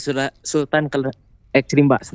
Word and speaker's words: Sultan X Rimba: Oke, ini kita bakalan nanya ini teman Pro 2 pastinya ini punya yang namanya Kedai Sultan X Rimba gitu bacanya atau Sultan Srimba nih bacanya gimana – Betul, Sultan X Rimba.